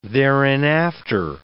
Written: thereinafter

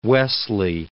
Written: Wesley

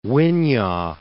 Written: Winyah Bay

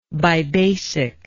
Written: bibasic

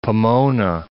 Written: Pomona

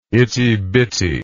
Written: ittybitty

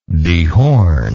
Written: dehorner

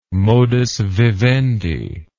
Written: modus vivendi

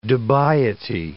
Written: dubiety

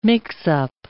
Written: mix-up

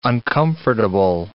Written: uncomfortable

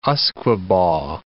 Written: usquebaugh

